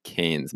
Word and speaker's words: Cairns: Cairns, or Australians say it like Cairns, mate.